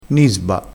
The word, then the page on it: nisba: dialettale